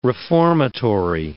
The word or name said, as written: reformatory